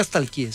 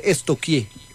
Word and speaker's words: stalkeé: Porque no se dice nunca stalkeé, se dice estoquié.